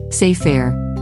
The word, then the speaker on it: sefer: defines this week's Jewish terminology word sefer as a Hebrew noun pronounced as sefer, meaning book.